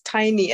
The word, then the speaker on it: Tiny: Maybe just for purposes of the interview, the way my first name is pronounced is tiny, as in something small.